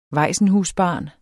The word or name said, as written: vajsenhusbarn